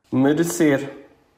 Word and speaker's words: merecer: Enquanto nós pronunciamos merecer. Merecer. Isto um pouco mais parecido com o castelhano do que com o português europeu, não? em termos de pronúncia. Portanto, nós abrimos as vogais todas.